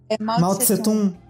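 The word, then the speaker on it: Mao Tsé-Tung: É, tem a mesma pronúncia lá, do jeito que a gente fala aqui, Mao Tse-Tsung? Ou então tem... – Mao Tse-Tung.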